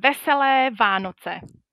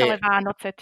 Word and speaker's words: Veselé Vánoce: So it says Veselé Vánoce. So I wish okay. Vesele Vánoce to all the listeners. – Vesele Vánoce.